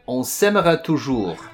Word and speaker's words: On s'aimera toujours: Well, it's Cœur de Pirate and On s'aimera toujours.